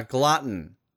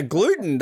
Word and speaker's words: glutton: I was about to say a gluten. That's a new one, but a glutton.